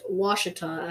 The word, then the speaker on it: Ouachita: Since 1871, sourced in a remote valley of the Ouachita, and is apparently that's pronounced Ouachita, actually.